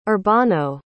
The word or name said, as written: urbano